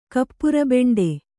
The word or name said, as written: ಕಪ್ಪುರಬೆಂಡೆ